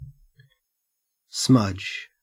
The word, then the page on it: smudge: Australian English